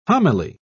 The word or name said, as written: homily